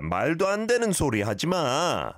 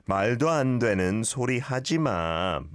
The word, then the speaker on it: 말도 안 되는 소리 하지 마: Maldo andenen sorry hajima. Like that. Maldo andenen sorry hajima. Not too much of a gap between maldo andenen and sorry, but good pronunciation.